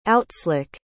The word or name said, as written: outslick